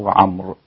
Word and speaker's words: عمرو: عمرو عين ميم راء واو عمرو، ولكنها تنطق عمرو، أبو عمرو البصري.